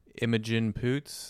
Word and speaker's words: Imogen Poots: Jordan, Miles Teller, Imogen Poots. Is that how you pronounce her name? – Yeah.